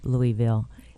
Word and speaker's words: Louisville: And so if you're named after King Louis, it's Louisville.